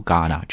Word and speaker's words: Ghana: In Ghana，这个国家叫加纳在非洲。然后注意一下这个单词的读法， H 这个字母在这里是不发音的，所以直接读 Ghana 就可以